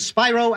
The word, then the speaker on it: Spiro: Later, he turned his attention to a political career, highlighted by a 1968 shoving match with then-Vice President Spiro Agnew. – Bailey leans forward and whispers the correct pronunciation to Les.